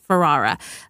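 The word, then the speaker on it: Ferrera: America Ferrara. I can't say her name. – Ferrara.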